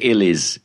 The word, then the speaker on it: Ilkeston: Ilkeston. – Ilkeston. – Yep, yeah, but apparently they pronounce it Illies in the local dialect.